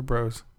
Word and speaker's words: Bros: Is it Brothers or Bros? – Well, we're going to pronounce Bros, but Super Smash Bros.